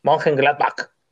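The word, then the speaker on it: Mönchengladbach: Monchengladbach.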